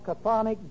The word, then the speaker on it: chthonic: This is called chthonic deity, C-H-T-H-O-N-I-C. Chthonic, actually the C-H sound is silent.